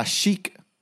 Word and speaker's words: Chic: By Chic, but pronounced by many in Arkansas as Chick.